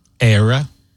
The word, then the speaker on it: Ayra: Like Ira Starr and Zayn. Mm-hmm. Is it Ira or Aira? – How do you spell it? – A-Y-R-A. – Oh. – Ira? Aira. Anyway, she's with Zayn and singing a song called Heaven Baby.